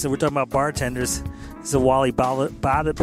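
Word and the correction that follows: Badarou: I never say his name. Hold on, let me try to pronounce it. Wally Badaru track.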